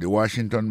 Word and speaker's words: Washington: Si n'ap mette accent sou accent encore, ou apwè ke mo Washington nan, accent tombe sou premier syllabe la. Pou mo monument, il tombe sou premier syllabe la. The Washington Monument.